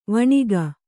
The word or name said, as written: ವೈಣಕ